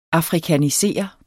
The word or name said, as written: afrikanisere